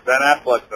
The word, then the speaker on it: Affleck: You need to My be favorite, Ben, ben Affleck's in that. – Oh my God, you pronounced his name right.